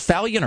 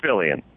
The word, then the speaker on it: Fillion: Is the last name pronounced Fallion or Fillion? – It's Fillion.